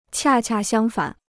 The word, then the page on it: 恰恰相反: qià qià xiāng fǎn